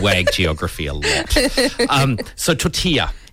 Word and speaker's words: tortilla: Do you say tortilla or tortilla? – Tortilla. – Tortilla. Tortilla. – I think that's how you say it, right? – It's a double L silent. – Yes. – Double L in, is it Mexican? – Spanish? – Spanish.